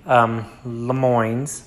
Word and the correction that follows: Le Mans: Of course, I know I'm not even saying that right, but Le Mans. There you go. I said it right that time.